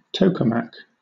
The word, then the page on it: tokamak: British English